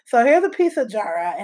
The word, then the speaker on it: Jaarae: The first one is called, um, I think it's pronounced Jara. It's spelled J A A R A E.